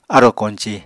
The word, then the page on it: arò koñci: Apatani